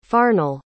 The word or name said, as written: farnell